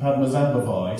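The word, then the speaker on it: Padmasambhava: and particularly you're all pronouncing Padma Zambavai exactly right.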